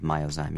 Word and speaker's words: Myozyme: Well, eventually Genzyme does come up with Myozyme. Am I pronouncing that correctly? – The drug that finally was approved for treatment of Pompe is now called Myozyme, yes.